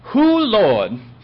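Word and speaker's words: Hoo Lawd: The pronunciation of the name is, and I have looked this up, Hoo Lord.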